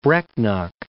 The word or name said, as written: Brecknock